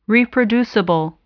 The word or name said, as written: reproducible